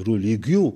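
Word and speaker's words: religio: هو تونسي تيرتوليان، وماذا بي تقتل تحل اليوم المعجم الألماني، المعجم الفرنساوي، المعجم الإنجليزي، المعجم الكبير، تلقى كلمة دين روليجيو وإلا روليجيو كما تنطقها باللاتينية، هي كلمة استعملها أول من استعملها معناها سيسيرون باعتبارها العلاقة بين المواطنين، علاقة روليجي يعني روليجاري معناها باللاتينية.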